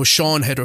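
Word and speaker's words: Siân Heder: And I'll credit the writer-director, is it Cian Heder is her name, I believe it is. – No, Sean Heder. – Is it Sean as I pronounce her name? Okay, it's written really weird. And she's only done it's the Welsh Welsh spelling.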